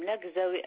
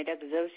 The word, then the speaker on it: Laghzaoui: Madame Lagzaoui Asnaï. Lagzaoui, c'est L-A-G-H-Z-A-O-U-I. Lagzaoui Asnaï, c'est H-A-S-N-A-E. Et mon mari, Lagzaoui Abdenbi. Lagzaoui, comme ça se prononce.